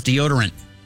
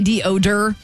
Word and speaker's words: deodorant: It's D-E-O-D-O-R-A-N-T. – Deodorant. – Yeah. – Mm-hmm. Dang. What did I say? Deodor? – Deodorant.